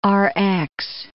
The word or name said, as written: Rx